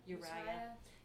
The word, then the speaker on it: Uriah: She was the wife of Uriah. Is that how you say it? – Uriah.